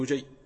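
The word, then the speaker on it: نُجَيّ: ومنها عبد الله بن يحيى، وهم جماعة، وعبد الله بن نجي بضم النون وفتح الجيم وتشديد الياء، تابعي معروف يروي عن علي رضي الله عنه.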